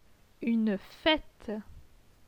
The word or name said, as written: Une fete